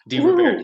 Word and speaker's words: DeRubertis: Well, I think it's fun to say it, with a little bit of the touch of the Italian roots, DeRubertis.